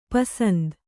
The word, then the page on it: ಪೂಸಂತೆ: pūsante